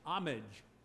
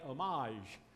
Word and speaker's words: homage: The British version is homage with the H pronounced. Our English-American version is without the H, homage. The French, it comes from France, the word, and they say homage. We don't say that. Our word is homage.